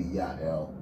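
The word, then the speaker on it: Jael: And I'm And you said her name is JL. That sounds French. – JL is actually Hebrew. It's a Hebrew name. We probably pronounce it in a French way because Hebrew would be Ya'el.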